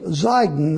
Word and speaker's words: Zidon: So the thing to realize here is the Lord takes him out of the land of Israel, and he comes to Zidon there. Now, it's spelled with an S in the New Testament. It's a Z in the Old Testament, but it's Zidon.